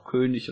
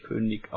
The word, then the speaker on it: König: Dafür solltet ihr das Wort König auch König und nicht König aussprechen.